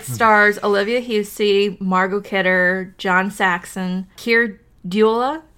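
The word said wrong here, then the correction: Dullea: Dulia? I can't pronounce his last name. – Keir Dulia.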